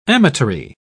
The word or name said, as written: amatory